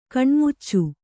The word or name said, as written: ಕಣ್ಣಾಮುಚ್ಚಿ